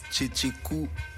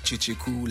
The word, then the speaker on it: Kyekyeku: My stage name is Cheche Ku, and it's written K-Y-E-K-Y-E-K-U, but pronounced Cheche Ku like two Che Guevaras and one Cool and the Gang, so that makes Cheche Ku.